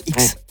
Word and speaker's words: IKKS: Déjà, j'ai appris que IKKS, en fait, ça veut dire X. – Bien sûr, on dit X. – Ça se prononce X. IKKS.